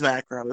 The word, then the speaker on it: Macro: Marco Cosmos. – Or Macro, depending how you want to pronounce it. – Oh, it is Macro. It's spelled M-A-C-R-O.